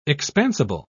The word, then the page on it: expansible: British English